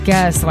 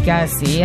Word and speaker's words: Kelce: Nos, Taylor Swift és Travis Kelce vagy Kelsey. Hát én akárkivel találkozom, aki amerikai mondjuk a TikTokon és kiejti a nevét, mindig kétféleképpen mondják, hogy Kelce vagy Kelsey.